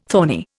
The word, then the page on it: thorny: Indian